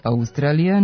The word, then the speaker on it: Australija: Oblik Australija naveden je u matičinom rečniku sa kratkouzlaznim akcentom na drugom slogu i dužinom na sledećem, tamo gde se u razgovornom jeziku obično čuje dugosilazni akcenat, što je van akcenatske norme, pošto silazni akcenti mogu da stoje samo na prvom slogu u reči.